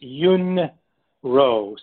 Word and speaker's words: Yun Rou: So the way to pronounce it is Yuan Ru. So you could think of it as if it were phonetically spelled Y-O-O-N and then R-O-W like row a boat.